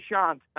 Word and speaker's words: Shant: It's actually Shant. – Oh, it's not S-H-A-N-T? – It is. It's S-H-A-N-T. It's pronounced Shant.